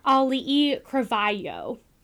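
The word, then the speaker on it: Ali'i Cravayo (Auliʻi Cravalho): The voice of Moana is Ali'i Cravayo is how she says her name.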